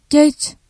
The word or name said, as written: geç